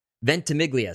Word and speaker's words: Ventimiglia: Ventimiglia.